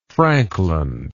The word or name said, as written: Frankland